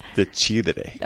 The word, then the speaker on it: decidere: decide comes from the Latin word decidere. – I think it's decidere. Oh, at least that's how you say it in Italian. And I'm assuming it's similar in Latin.